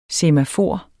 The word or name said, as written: semafor